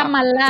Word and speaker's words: Kamala: Creo que Kamala, es Kamala. – Kamala.